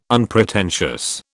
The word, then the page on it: unpretentious: British English